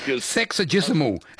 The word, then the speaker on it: sexagesimal: Sexagesimal.